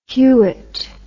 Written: Hewett

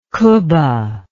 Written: qubba

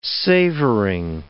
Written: savoring

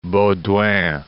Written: Baudouin

